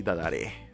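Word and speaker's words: DADARAY: No, al final no salió como esto, pero estaba escuchando mucho un grupo japonés que se llama Dadarai, que lo pronuncian como Dadarei o algo así, Dadarei. – Daray. Creo que, creo que hemos recomendado canciones de ellos. De sí, ¿verdad? Bueno, nosotros le decimos Daray porque así se escribe.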